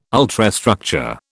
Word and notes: ultrastructure: British English

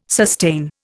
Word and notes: sustain: Indian

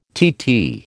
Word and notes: TT: American English

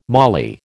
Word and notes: mali: American English

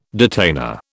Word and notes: detainer: British English

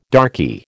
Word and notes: darkie: American English

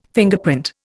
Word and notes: Fingerprint: Indian

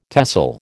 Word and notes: TESOL: American English

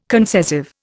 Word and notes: concessive: Indian

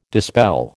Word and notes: dispel: American English

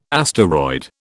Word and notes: asteroid: British English

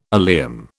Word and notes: oleum: British English

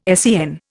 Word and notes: SEN: Indian

